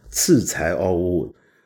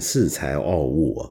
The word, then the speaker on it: 恃才傲物: 但是我差点漏了很重要的本周道歉时段啊，那就是我上次做节目果然又读错音了，把四才傲物读成了次才傲物。呃，这这个，我常说我的普通话说不好。不一定是因为我的广东话是母语的关系，但这个真是广谱了。为什么呢？因为在粤语里面，恃才傲物啊，我们粤语常常是读成奇才傲嘛。